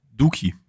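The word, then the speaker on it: Doekhi: Ähm, wird der Dürki oder Durki, wie wird er ausgesprochen? Duki. – Von mir aus Duki. Steht am 16er frei. Also glaube ich zumindest. Wir nennen ihn Duki. Reingelaufen. Ich meine, OE ist doch in den Niederlanden immer U, oder?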